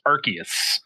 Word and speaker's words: Arceus: I saw an actual pronunciation explanation of it, and it is Arceus.